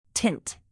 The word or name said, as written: tint